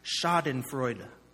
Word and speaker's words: Schadenfreude: There's a German word, and I had to look it up so that I could say it properly, Schadenfreude.